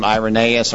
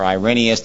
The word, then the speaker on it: Irenaeus: External evidence are, like Melanie mentioned, there's a fellow named Irenaeus or Irenaeus, depending on how you want to pronounce it.